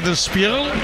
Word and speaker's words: Spiegel: He flipado con el arranque y la pronunciación de Spiegel, periódico alemán. He flipado. Spiegel.